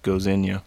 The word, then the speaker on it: Gdynia: And this film was playing at the Gdynia Polish Film Festival. And from what I read, it's pronounced Gozynia. – Gozynia.